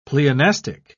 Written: pleonastic

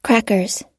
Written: crackers

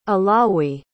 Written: alawi

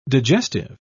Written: digestive

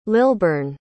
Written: lilburn

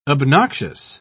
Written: obnoxious

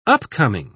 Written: upcoming

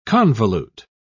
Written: convolute

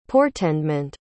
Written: portendment

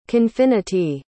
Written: confinity

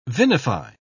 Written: vinify

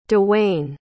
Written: dewain

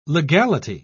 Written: legality

